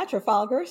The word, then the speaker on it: Trafalgar: And sorry if I mispronounced this, Trafalgar. Does that sound right? – Yeah, Trafalgar Strategies.